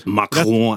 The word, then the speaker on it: Macron: Det er vel Macron som er lett. Macron rett. er jo rett.